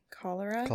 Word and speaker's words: cholera: And especially during the, how do you say this? The C-H-O-L-E-R-A? Cholera? – Cholera.